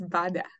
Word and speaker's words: Badea: I never asked how you say your last name. – It's Bada.